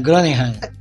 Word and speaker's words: Groningen: Gronenheim, é assim que pronuncia? É assim? É. – É, Groningen.